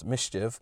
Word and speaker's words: MSCHF: But NBC News reports that Lil Nas X, the Old Town Road artist, is collaborating with streetwear company MSCHF, which is actually pronounced Mischief, on a pair of Satan shoes, only 666 pairs of which went on sale Monday.